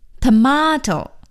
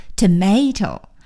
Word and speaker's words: tomato: 好了，说完了干酪，咱们再来看看这个西红柿的说法，tomato， T-O-M-A-T-O， tomato，西红柿，tomato，美音的发音呢是念成 tomato， T-O-M-A-T-O， tomato，所以 tomato 和 tomato 都是对的。